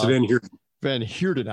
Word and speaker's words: Van Heerden: He's like 16-1 to knock out this Chris Verheiden, or however you say his name. – Chris Van Heerden. – I was close.